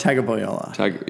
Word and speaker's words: Tagovailoa: How do you say it? Tagovailoa. – Tagovailoa.